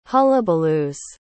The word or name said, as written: hullabaloos